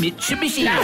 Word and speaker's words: Mitsubishi: Now there's Ford, there's Kia, Hyundai and Mitsubishi. That's how you say it.